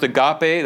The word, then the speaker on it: agape: Agape. You've heard it pronounced agape, that's okay. Modern Greek would say agape.